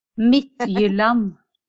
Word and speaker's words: Midtjylland: Midtjylland. – Midtjylland.